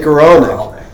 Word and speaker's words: Goralnik: Jake Goralnik.